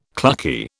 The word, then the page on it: clucky: British English